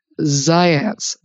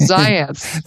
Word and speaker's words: Zajonc: If I say his name, I'll have to spell it for everybody, because I believe it was pronounced Zayance, but I think he was Yugoslavian or something.